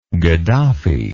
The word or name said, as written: gaddafi